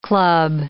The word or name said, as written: club